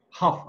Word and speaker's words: Huff: So you have the last name of Grant and you look like Juliet Ho. Am I pronouncing her name? It just sounds like every time I Huff. – Okay, Julianne Huff. That sounds a lot better.